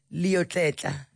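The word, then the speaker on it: Leotlela: Yo, I think Thembikile told me I'm saying that surname wrong. Oh, Gift Lotletla. Lotletla.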